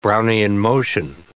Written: Brownian motion

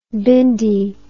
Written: bhindi

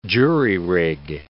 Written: jury-rig

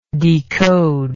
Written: decodable

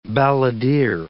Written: balladeer

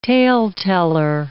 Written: tale-teller

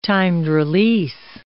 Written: timed-release

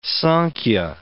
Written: Sankhya

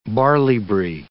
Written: barley-bree

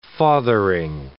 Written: fathering

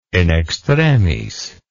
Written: in extremis

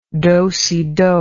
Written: dosido

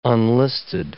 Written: unlisted

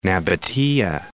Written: Nabatea

